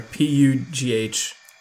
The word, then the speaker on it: Pugh: How do you say that? P U G H. – We need your expertise. – Pugh. – That makes more sense. – I think.